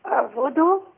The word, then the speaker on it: اعبدوا: ألف الوصل تبدأيها ازاي؟ – اعبدوا. – ايه؟ – اعبدوا. – اعبدوا. اعبدوا. ايوه بالضم. تمام؟ – قال يا قوم.